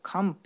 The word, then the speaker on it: 汗疱: で、お子さんに多いのは、汗の水ぶくれと書いて汗疱と読むんですけど、お子さん、小さいお子さんの足の裏の皮がむけるっていうのは、多くの場合水虫じゃなしにこの汗疱ですね。